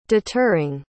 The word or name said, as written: deterring